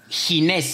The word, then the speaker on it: Ginés: Ginés, ¿cómo se escribe? ¿Con J? – Ginés. – Con G. – G-I-N-E-S García.